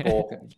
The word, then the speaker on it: Boorcan: But it's, you just put a little bit too much emphasis on the can. – Can. Boorcan.